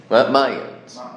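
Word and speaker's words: Mainz: This is apparently some famous story that happened in, how do you pronounce it in English? Magens? Magens? Mayans. Mayans. Mayans.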